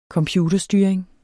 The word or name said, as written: computerstyring